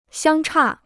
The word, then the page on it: 相差: xiāng chà